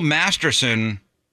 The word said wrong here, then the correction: Masterton: I'm saying it wrong. The Bill Masterton Memorial Trophy, presented by the Professional Hockey Writers Association to an NHL player who shows perseverance, sportsmanship, and dedication to the game.